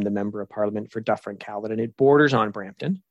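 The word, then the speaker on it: Dufferin–Caledon: Yes, Dufferin Caledon.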